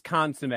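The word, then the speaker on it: consommé: For our regular American listeners out there, it's consomme is how we pronounce it. Like, okay. Yeah. Consomme.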